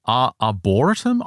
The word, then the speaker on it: arboretum: What is an arboretum? I don't know that arboretum. – Word. Arboretum? Is that how you pronounce it? – Yeah,